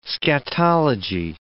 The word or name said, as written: scatology